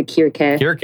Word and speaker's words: Circe: Some people go with the Greek, the Kyrke. – That does help us Cersei. Distinguish here. – It is. But we'll just keep going. I like Cersei or Circe.